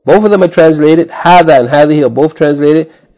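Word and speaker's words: Hadha (هذا): In the same way that we said about hadha. The alif is not written, but it should be pronounced the first syllable long. Hadha.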